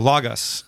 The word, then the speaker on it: Logos: It's Logos, and the reason is Logos, this is too much information, is because what are transliterated as O's are omicrons. – Yes. – Not omegas. If they were omegas, it would be pronounced logos, but they're omicron, so it's logos.